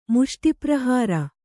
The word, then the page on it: ಮುಷ್ಟಿಪ್ರಹಾರ: kannada